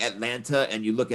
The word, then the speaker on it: Atlanta: That's how you pronounce it, by the way. Atlanta, N-U-H on the end. I'm from Atlanta. If you say Atlanta, that's an automatic sign you're not from Atlanta.